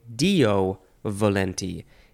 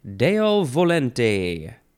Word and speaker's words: Deo volente: You could also say Dio volenti. That one, most people prob- probably say that because it is spelled capital D E O, next word V O L E N T E.